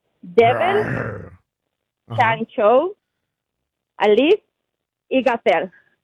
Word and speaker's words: Shang-Chi: Así dijiste tú, Shang-Chi. – Es que yo lo dije como se pronuncia. Nosotros que somos chinos.